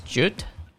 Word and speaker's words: Judd: For some reason, when he listened to podcast episodes from this guy called Curtis Jude, I think that's how you pronounce his last name. He's on YouTube. He's basically a YouTuber, Curtis Jude, I think, J-U-D-D.